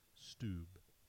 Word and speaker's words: Stubbe: It stood up and turned out to be a middle aged man named Peter Stubbe from the same village. His last name is spelled S T U B B E. Stubbe, they put him on the torture wheel because it's 1591.